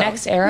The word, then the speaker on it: NextEra: NextEra? – NextEra.